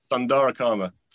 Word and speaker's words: Sundara Karma: This is Happy Family by Sundarama Karma. – Sundara Karma. – I definitely said it wrong.